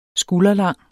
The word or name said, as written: skulderlang